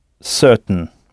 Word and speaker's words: certain: Si certain se dit tout simplement certain, ça s'écrit comme en français, mais ça se prononce certain.